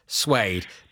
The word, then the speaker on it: Blue Swede: It's a Swedish band, spelt Swede, but pronounced Suede.